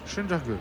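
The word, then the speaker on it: Söndörgő: Schundergue. C'est écrit Sondorgo, mais ça se prononce Schundergue.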